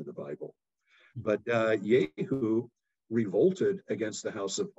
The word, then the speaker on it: Jehu: But Jeroboam um, ben Joash, son of Joash, was the fourth member of the house of Yehu. Um, you might pronounce that Jehu, depending on how you read the English uh, translations.